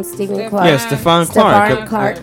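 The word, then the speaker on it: Stephon Clark: Stephen Clark got shot and killed 20 times in his own backyard of his grandmother's house.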